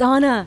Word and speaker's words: Anna: Little touch-up for Anna. For yeah. Anna. – I know. It's a whole thing. Thanks. – It is Anna, right? – It's Anna. – It's Anna.